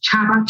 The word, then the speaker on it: Chaba: Junita, you spell it with J-U-N-I-T-O-R. And then my surname is Chaba. Chaba is C-H-A-B-A.